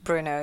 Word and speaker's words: Brno: So we've just watched the, is it pronounced Bruno? – Bruno, yeah. – Bruno. MotoGP race in the Czech Republic, and it was a pretty good day.